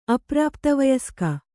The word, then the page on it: ಅಪ್ರಾಪ್ತವಯಸ್ಕ: aprāptavayaska